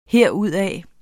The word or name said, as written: herudad